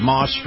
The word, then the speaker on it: Maus: This portion of the program brought to you by my friends at Moss Family Automotive. That's spelled M-A-U-S, Moss Family Automotive.